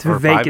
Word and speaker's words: Vivek: But no, it's Vivek is how you say it.